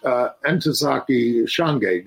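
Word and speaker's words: Ntozake Shange: He also published Entezaki Shange. Did I say her name right? – You did.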